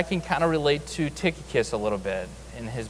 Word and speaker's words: Tychicus: Tychicus. Tychicus.